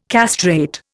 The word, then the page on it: castrate: Indian